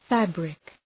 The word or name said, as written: fabric